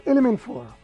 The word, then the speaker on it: Element 4: ¿Se dice así? Element 4. – Lo llamamos Element 4.